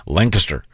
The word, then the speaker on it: Lancaster: While I'm at it, I will point out that Pat Godwin is going to be doing a fundraiser in Lancaster, Ohio. Did I pronounce it right? – Oh, that was good. – Lancaster.